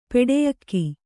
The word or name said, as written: ಪೆಡೆಯಕ್ಕಿ